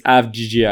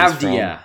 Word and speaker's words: Avdija: still not getting any credit because you didn't pronounce his name properly. – It's Avdija. I don't know where he's Avdija.